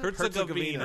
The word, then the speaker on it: Herzegovina: How do you pronounce it? – Herzegovina. – Herzegovina. Okay.